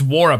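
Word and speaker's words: Nwora: It's WARA, by the way. The N is silent, not the W.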